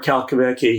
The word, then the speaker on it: Calcavecchia: And you probably would pronounce it Calcavecchia. But his name is Mark Calcavecchia.